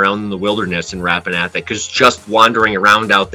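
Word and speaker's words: Rappan Athuk: but I don't remember how it's actually pronounced. – It is pronounced Rapanathok.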